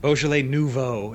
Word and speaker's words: Beaujolais Nouveau: Beaujolais Nouveau. Is that how you say it? – Beaujolais Nouveau. It's Nouveau. I was going to say that. Okay, all right.